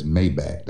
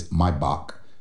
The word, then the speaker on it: Maybach: Maybach Music, even though he says Maybach. That's not how you pronounce that car. It's German. It's Maybach Music.